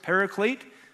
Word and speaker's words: paraclete: Advocate, the Greek word is parakletos. We usually pronounce it paraclete.